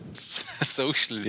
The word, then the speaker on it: So.cl: Wie spricht man das überhaupt aus? Social. Sockel, oder? – Social, ja.